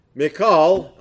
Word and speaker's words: Michal: And that's why, you might wonder about this, that's why later David's wife, Michal, I know it looks like Michael, but it's pronounced Michal in Hebrew.